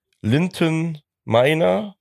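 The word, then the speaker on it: Linton Maina: Dann eine safe Kiste, Linton Meiner von Hannover 96. Ich hoffe, ich habe es richtig ausgesprochen. – Ja, so hätte ich es auch ausgesprochen.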